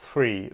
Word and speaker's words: Thuy: All right, so Steve now, and after Steve, I believe it's pronounced three that we're going to come to. THUY, we're going to come to you after Steve Downing, who is now good to go.